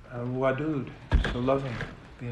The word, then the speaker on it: wadud: Even though it's pronounced wadud, it's spelled vav dal, W-D-W-D. It's like whirling. W-D-W-D, W-D, wadud, wadud, wadud.